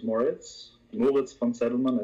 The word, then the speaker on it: Moritz von Zeddelmann: My name is Moritz von Zeddelmann, as you would correctly pronounce it in German.